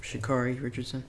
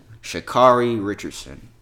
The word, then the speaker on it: Sha'Carri Richardson: Sha'Carri Richardson. – Sha'Carri Richardson.